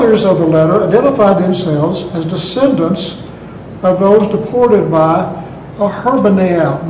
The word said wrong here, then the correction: Ashurbanipal: How do you pronounce that word? – Ashuras? – Ashurbanipal. – No? Oh, Ashurbanipal. – That sounds right. – There you are, Ashurbanipal.